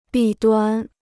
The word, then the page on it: 弊端: bì duān